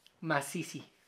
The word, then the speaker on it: masisi: Masisi. Masisi. Masisi es igual a maricón. Suena como mazorca. No, masisi.